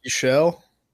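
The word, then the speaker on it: Buechele: How do you say Shane's last? Shane Buschelle? – Buschelle.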